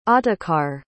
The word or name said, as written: ottokar